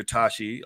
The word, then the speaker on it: Tachi: I'm here with Dr. Tachi, who is the founder of Indie Soup Media.